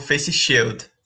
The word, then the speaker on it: face shield: A máscara sempre, menos o face shield. Essa daí eu ainda não usei, não. – Como que se pronuncia? – Face shield. – Face shield.